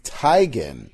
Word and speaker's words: Teigen: Chrissy Teigen, much like Ariana Grande, we've been mispronouncing Chrissy Teigen's last name. The correct pronunciation of Chrissy Teigen's last name is Teigen.